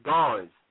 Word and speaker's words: Garnes: Is Ramel, R-A-M-E-L. – Okay. – My last name is Garnes, G-A-R-N-E-S. – G-E-R-M-E-S? – G-A-R-N-E-S. – Oh, okay, for sure. Nigga, I had R-A-E-L-L.